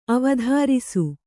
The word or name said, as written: ಅವಧಾರಿಸು